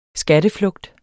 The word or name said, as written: skatteflugt